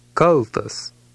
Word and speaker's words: kaltas: It has a short followed by a hard L. The tilde over the L means that it should be pronounced emphatically. Read number 2. – Kaltas.